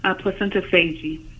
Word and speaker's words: placentophagy: And just to clarify, you've said the formal name is placentophagy. Is that how you say it? – Placentophagy.